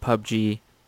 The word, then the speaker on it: PUBG: And then his second conclusion is that people who play Fortnite and PUBG... No, did I say that wrong again? – No, you got I got it. – Right this time.